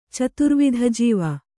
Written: ಚತುರ್ವಿಧಜೀವ

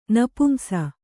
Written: ನಪುಂಸ

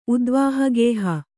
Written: ಉದ್ವಾಹಗೇಹ

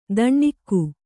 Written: ದಣ್ಣಿಕ್ಕು

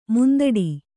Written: ಮುಂದಿಡು

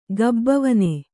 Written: ಗಬ್ಬವನೆ

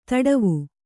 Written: ಧಡವೆ

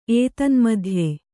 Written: ಏತನ್ಮಧ್ಯೆ

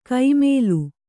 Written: ಕೈಮೇಲು

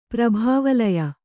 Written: ಪ್ರಭಾವಲಯ

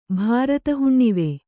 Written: ಭಾರತಹುಣ್ಣಿವೆ

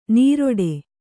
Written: ನೀರೊಡೆ

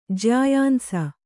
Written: ಜ್ಯಾಯಾಂಸ

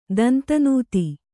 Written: ದಂತನೂತಿ

